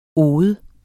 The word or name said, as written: ode